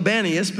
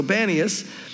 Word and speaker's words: Banias: They call it Banias because they can't pronounce the P's. They say B's instead of P's, so Banias.